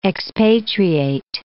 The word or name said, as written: expatriate